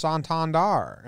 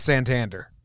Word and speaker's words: Santander: They were calling him Anthony Santander, and then they were like, actually, We just found out it's pronounced Santander. And then a lot of the announcers were doing that. And then they're like, wait, never mind. Correction. – Bring it back. – It's Santander. Like, obviously.